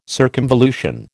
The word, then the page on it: circumvolution: American English